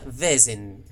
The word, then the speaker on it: Vaesen: Pues aquí lo cierto es que yo tampoco lo tenía muy claro y preguntamos a, los autores originales y nos dijeron que se pronuncia Vesen, con una así alargada.